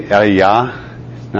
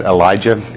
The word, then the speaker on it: Elijah: So it's really Eliyah, not Elijah, as we pronounce.